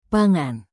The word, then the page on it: pangan: Indonesian